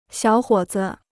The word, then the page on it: 小伙子: xiǎo huǒ zi